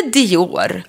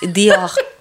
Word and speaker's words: Dior: Notera uttalet, kära lyssnare. Inte Dior, utan Dior.